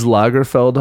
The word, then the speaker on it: Lagerfeld: Carl, is Lagerfeld how you pronounce that? – Yeah.